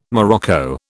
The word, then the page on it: morocco: British English